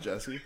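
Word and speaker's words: Djesse: Jesse with a D in front of it. – The D is silent. – Hillbilly. Yeah, this is just Jesse.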